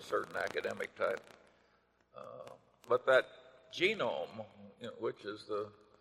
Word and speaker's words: Genome: Well, I mentioned that one book, Genome. I have a hell of a time putting the accent on the first syllable.